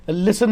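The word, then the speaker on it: listen: कई वर्ड्स होते हैं जिसमें एक अल्फाबेट साइलेंट होता है, जैसे कि लिसन में टी साइलेंट होता है, सैमन में एल साइमन साइलेंट होता है, कासल में टी साइलेंट होता है, रिसीप्ट में पी साइलेंट होता है.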